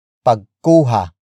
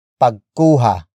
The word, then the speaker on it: pagkuha: Word for word, it's para sa pagkuha ng litrato. – para, sa, pagkuha, ng, litrato, para, sa, pagkuha, ng, litrato.